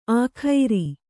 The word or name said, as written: ಆಖೈರಿ